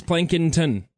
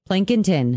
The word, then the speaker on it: Plankinton: By the way, I did get a phonetic pronunciation from a Plankintonian. – Okay. – And it is Plankinton. – Plankinton.